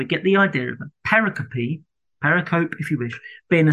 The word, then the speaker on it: pericope: Pericope, I want to introduce, and I have been corrected on the pronunciation of this slightly. So the English word we pronounce pericope is a division of a section of text. It comes from the Greek word perikope, I think, or something like that.